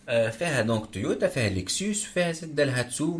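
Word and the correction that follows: Daihatsu: مي دالهاتسو ما دايهاتسو ما كيفاش تنطق دايهاتسو اي دايهاتسو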